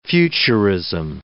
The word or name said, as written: futurism